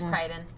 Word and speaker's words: Crichton: It's Crichton.